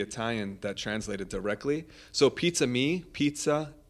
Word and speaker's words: Pizzami: Pizza Me, people. Uh, mistakenly pronounce it as pizzami.